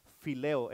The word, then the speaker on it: phileo: El primero ahí en tus notas es fileo, es, se escribe fileo, se escribe con P y con P y luego H, PH es, ah, viene escribiendo P-H-I-L-E-O, fileo. Amén. P-H-I-L-E-O.